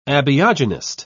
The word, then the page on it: abiogenist: British English